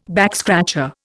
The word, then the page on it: backscratcher: Indian